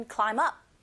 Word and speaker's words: climb: Climb의 b 사운드 묵음인 거 당연히 아실 테고, climb의 b 소리 안 나니까 climb up이라고 해서 사운드 자음과 up의 어라는 모음이 연결돼서 들리기 때문에 climb up 이렇게 소리가 들리게 됩니다.